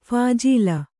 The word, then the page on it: ಫಾಜಿಲ್: phājil